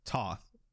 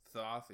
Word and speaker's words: Thoth: One of the strongest meta picks is Toth, however you want to pronounce it. – Thoth, yeah.